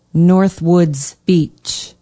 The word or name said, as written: northwoods beach